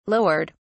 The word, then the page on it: lowered: British English